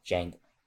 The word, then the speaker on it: Django: The D, the, the, the D is silent. – Oh, Jan- oh, Jang. Oh, like the movie Django yeah. Unchained. Yep, spell, pronounced the same way.